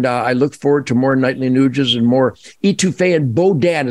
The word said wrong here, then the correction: Boudin: Is that how you pronounce it? Bodan? Is that that sausage? – Man, it's some Boudin. – Boudin.